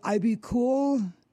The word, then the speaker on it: IBKUL: Is it IB Cool? – Yes, IB KUL. It's pronounced IB Cool.